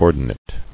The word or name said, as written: Ordinate